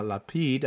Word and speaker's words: Lapid: Israel Prime Minister uh, Lapid, I, am I pronouncing that correctly? Yeah, correct.